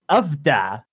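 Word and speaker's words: uff da: How exactly do you pronounce it? I'm not from the Midwest. Do you know? It's ufda. – I'm trying to see like how you pronounce it. It seems like it's ufda.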